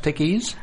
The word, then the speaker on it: Teguise: Teguise. Is it pronounced Teguise? – Teguise, yes. We call it. – Teguise.